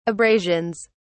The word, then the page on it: abrasions: British English